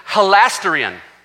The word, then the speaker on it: hilasterion: The word for atoning there is called, and pardon me if I mispronounce it, hilasterion. Hilasterion, H-I-L-A-S-T-E-R-I-O-N.